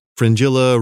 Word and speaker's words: Fringilla: Fringilla rhymes with Godzilla.